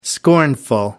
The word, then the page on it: scornful: American English